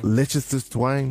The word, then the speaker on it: Liechtenstein: Liechtenstein. – Liechtenstein.